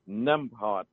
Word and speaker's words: Nembhard: Her name is Jessica, J-E-S-S-I-C-A, Gordon, G-O-R-D-O-N. – Okay. – And it's Nembhard, N-E-M-B, as in brave, hard, H-A-R-D, Nembhard.